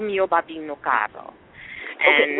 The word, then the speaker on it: caro: Say it right? – Caro. – Like you got it. Yeah, caro.